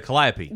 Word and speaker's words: calliope: Oh, the calliope. – The calliope.